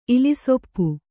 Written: ಇಲಿಸೊಪ್ಪು

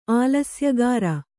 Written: ಆಲಸ್ಯಗಾರ

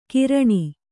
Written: ಕಿರಾಣಿ

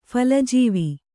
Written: ಫಲಜೀವಿ

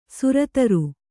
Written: ಸುರತರು

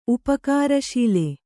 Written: ಉಪಕಾರಶೀಲೆ

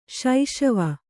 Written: ಸಿಸವೆ

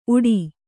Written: ಉಡು

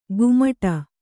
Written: ಗುಮಟ

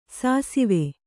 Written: ಸಿಸವೆ

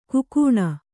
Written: ಘಕ್ಕನೆ

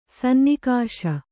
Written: ಸನ್ನಿಕಾಶ

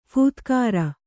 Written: ಫೂತ್ಕಾರ

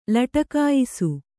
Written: ಲಟಕಾಯಿಸು

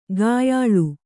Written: ಕೊಯ್ಯಾಳ್

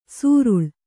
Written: ಸುರುಳ್

